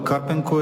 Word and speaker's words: Карпенко: Естествено, Людмила Карпенко се опита да докаже, че всъщност това са преосмислени формулировки от евангелските и въобще библейските текстове. Людмила Карпенко е, или Карпенко, както е по-точно според ударението украинското, намери онези библейски стихове, новозаветни и старозаветни, които отговарят на тази религиозно-философска трактовка въобще на създаването на дадена азбука.